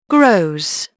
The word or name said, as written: grows